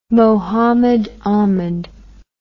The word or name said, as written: Mohammed Ahmed